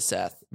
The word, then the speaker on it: Seth: I think it is spelt Seth, but it's pronounced Set.